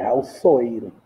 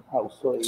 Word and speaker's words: Raul Soeiro: E eu queria saber se é Raul Soeiro, sou Soeiro, como é que como é que é o como é que é a, a pronúncia? – É Raul Soeiro, Raul Soeiro.